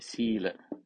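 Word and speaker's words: sīla: Sila, sila. Sila is long A. Use this diacritical mark hyphen to note longer pronunciation.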